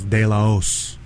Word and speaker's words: De La Hoz: You know, well, okay, my last name technically is De La Hoz. You know, like the H is silent.